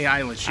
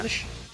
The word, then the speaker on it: Eilish: Eh, how do you say her last name? Ilish? I, am going to say Eilish. Yeah. Eilish have uh, jumped on board for the campaign for this particular season.